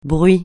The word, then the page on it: bruit: francais